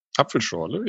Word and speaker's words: Apfelschorle: They say it in Austria and Germany, they say it differently, but Apfelschorle. – Apfelschorle, yeah.